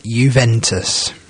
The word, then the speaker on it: Juventus: It's even difficult to find teams abroad in the top leagues in Europe other than Juventus. So, yeah. And that's almost a silent J, isn't it? It's a J as an I, nearly. The sort of Roman way, so to speak.